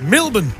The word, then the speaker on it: Melbourne: They're from Melbourne. Pronounced Melbourne.